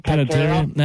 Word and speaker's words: Panettiere: How do you say her last name? Hayden Pantera? Panettiere? – Panettiere.